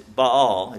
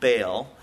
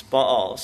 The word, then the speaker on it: Baal: And I found out the right pronunciation. It's Baal. It's not Baal. It's Baal.